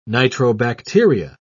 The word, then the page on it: nitrobacteria: British English